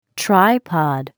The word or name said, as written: tripod